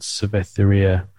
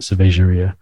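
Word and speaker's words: Cervejaria: There's a place in Portugal, in Lisbon, called Cerveteria. That's not how you say it, Cerveteria.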